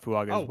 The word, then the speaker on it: Fuaga: Just want to point out once again, Fuaga, is that how you say it? – Mm-hmm.